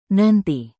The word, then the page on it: nanti: indonesian